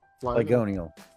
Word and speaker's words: Flygonal: Am I saying that right? – Flygonal. – Flygonal. Correction. Yeah. Let me bring that up. – Like the Pokemon.